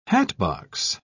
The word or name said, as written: hatbox